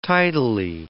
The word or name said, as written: tidally